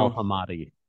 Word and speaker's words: Ali Al-Hamadi: And we signed Ali Howe Hamadidi, I think that's how you pronounce it. The Iraqi international. – Ali Al-Hamadi.